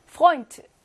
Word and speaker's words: Freund: Freund, 에우, 요거 뭘로 발음해? 오이로 발음하죠? 오이, 오이.